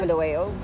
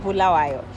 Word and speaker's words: Bulawayo: That's how you say it, not Bulawayo, Bulawayo.